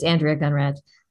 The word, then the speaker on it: Andrea Gunraj: Yeah, it's Andrea Gunraj.